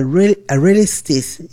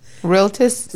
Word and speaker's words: Realtist: She is a broker, and not just another broker, a realtist. How do you pronounce that? – Realtist. – Realtist. – Yes.